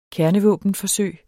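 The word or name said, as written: KERNEVÅBENFORSØG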